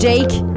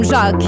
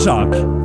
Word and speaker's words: Jacques: Ouais, ben, j'avoue, c'est écrit Jacques, pas comme Jake, comme Jacques. – C'est Jacques. – Tu sais, genre Jacques Brel.